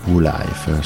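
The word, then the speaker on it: WU LYF: il cui nome in realtà significa World United Lucifer Youth Foundation, si pronuncia in realtà Woo Life.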